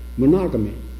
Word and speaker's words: monogamy: And I thought monogamy was the right way to say it. He went to the blackboard and wrote monogamy, but he said, no, this is the way it's marked, monogamy. Like bigamy, monogamy, no, it's monogamy.